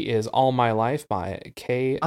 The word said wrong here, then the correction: K-Ci: K-C? No, it's Casey. Casey. – Yeah, well, it's I know it's pronounced Casey, but when I was looking the song up, it is spelled out K-C-I.